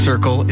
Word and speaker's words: CRCL: CRCL, pronounced Circle, is a free mobile crisis response service serving the Comox Valley.